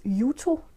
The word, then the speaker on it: Juto: Jeg tror, hendes F-navn udtales Juto. Det øh, staves i hvert fald J-U-T-O.